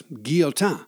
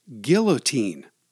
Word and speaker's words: Guillotin: In French, it's pronounced guillotin, but in English, we say guillotine.